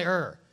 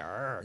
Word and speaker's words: Ur: Everybody say Ur. Don't say it like a lion. Like Ur. No, just Ur.